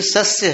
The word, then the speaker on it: सस्य: नहीं, वहां श नहीं है, वो श उच्चारण अशुद्ध है, वास्तव में स है, साधु वाला स है। वो थोड़ी चूक है। तो ये सस्य है, तो सस्य का अर्थ होता है धान्य।